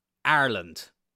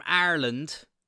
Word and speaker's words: Ireland: Another thing that gets on my nerves, and I've spoke about this before, the way some people who are from this country pronounce Ireland. I have heard so many people say Ireland. I'm from Ireland. Like, let's spell Ireland. I-R-E-L-A-N-D. Ireland. Not A-R-E-L-A-N-D, Ireland.